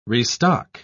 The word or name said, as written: restock